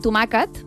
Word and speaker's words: tomàquet: Que és com tomàquet i tomaquet. El diminutiu de tomàquet. – És veritat. – S'escriu igual que tomàquet, però es pronuncia diferent.